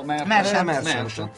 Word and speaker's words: Merchant: e Stephen Merchant, giusto? – Merchant.